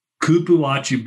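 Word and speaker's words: cupuaçu: What about, is it pronounced cupuachu butter? – Cupuachu butter. Yes.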